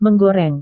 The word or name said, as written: menggoreng